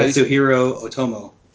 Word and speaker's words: Katsuhiro Otomo: I could name, I may not pronounce this correctly, Katsuhiro Otomo. – There you go.